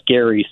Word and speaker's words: Gerry: I mean, if you actually want to go back to Massachusetts, it goes back to Governor Elbridge Gerry, and the family pronunciation was Gerry. So if we wanted to go back that far, we would pronounce it gerrymandering, and I always tell my students that.